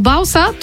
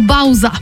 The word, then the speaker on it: Bausa: Tylko tutaj trzeba być czujnym, bardzo, bo Bausa tutaj w naszym przypadku to jest takie norweskie trio, a jest też raper, którego pisze się dokładnie tak samo, ale jest to Bauza, prosto z Niemiec.